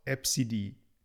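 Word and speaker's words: Abcde: Und das spricht man aus Epsidi.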